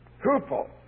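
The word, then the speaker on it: hupo (ὑπό): There's a Greek word, preposition, for under, which I'll just give you the English pronunciation phonetically. It's hupo.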